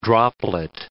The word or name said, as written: droplet